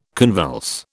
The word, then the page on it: convulse: British English